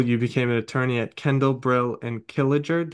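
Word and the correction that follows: Klieger: It's Kendall, Brill, and Kleeger.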